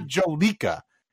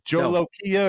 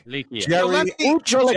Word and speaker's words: Jolokia: I don't know how it's pronounced, but what I do know, it's not Jolica. It's not Jolica. It's Jolokia or Jolokia. What is no.